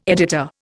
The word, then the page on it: editor: Indian